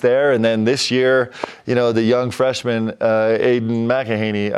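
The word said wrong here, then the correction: Aidan Mahaney: I said the name wrong. – Aiden Mahaney. – There we go. I gave him an extra syllable, which is...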